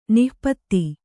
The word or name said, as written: ನಾಪಿತ